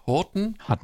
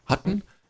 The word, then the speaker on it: Hutton: 5 Days to Midnight mit Oscar-Gewinner Timothy, wie spricht man eigentlich aus? Horton? – Hutton.